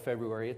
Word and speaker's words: February: If you look up February, it's pronounced February. And I think there's actually a linguistic reason why that's actually always been pronounced that way.